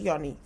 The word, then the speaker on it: yoni: Is it yoni? Is that how we pronounce it? Yoni. Is that how you yeah, pronounce yoni, it? Yoni. Yeah, yeah, yeah, yeah, yeah, yeah.